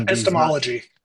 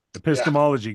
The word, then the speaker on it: epistemology: What do we know from a religious epistemology? What do we know from a scientific epistemology? I hope I'm saying those right. Epistemology. – Epistemology. Yeah. Good.